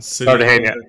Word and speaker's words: Cartagena: Cartagena. – Cartagena.